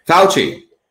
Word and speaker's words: Fauci: Fauci. – Fauci, I'm not a fan.